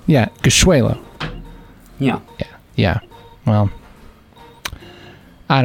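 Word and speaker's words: Gashweylo: Gishwelo.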